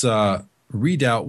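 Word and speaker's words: redoubt: That's readout with a B. It's a silent B. R-E-D-O-U-B-T.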